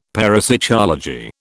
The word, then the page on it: parapsychology: British English